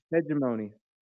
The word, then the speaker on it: hegemony: I always screw it up, homogeny, is that correct? Or how do you say that word? Hegemony.